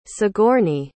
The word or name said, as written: sigourney